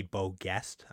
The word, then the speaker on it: Beau Geste: Could be Beaugest. I think it's probably pronounced Beaugest in America, but I did look up the pronunciation. It was closer to jest.